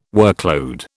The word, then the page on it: workload: British English